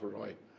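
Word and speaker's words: Ray: He writes Rai, it's pronounced Roy.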